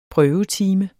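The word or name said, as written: PRØVETIME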